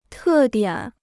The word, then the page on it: 特点: tè diǎn